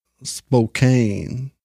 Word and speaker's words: Spokane: Travis is the one who told me that's how it's pronounced, so. – Spokane. – Spokane. – It's actually K-A-Y-N-E.